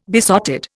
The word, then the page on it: besotted: Indian